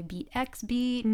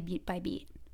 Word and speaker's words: Beat by Beat: A lot of people don't really know how to say it. Sometimes they say Beat X Beat, mm-hmm, or Beat Beat, like they're not really sure, but it's actually Beat by Beat.